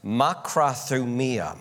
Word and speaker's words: makrothumia: This is a Greek word that's pronounced makrothumia, makrothumia, makrothumia, thumia.